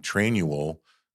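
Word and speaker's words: Trainual: train-you-will, the way it's spelled, train, U-A-L, is a platform we have found.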